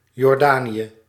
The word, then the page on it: Jordan: /ˈdʒɔːdən/; /ˈdʒɔːrdən/